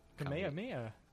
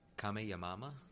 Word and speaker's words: Kamehameha: I liked all the videos of the people pronouncing it incorrectly. – Kamehameha. – Kamehamama. I saw that one and I was like, wow.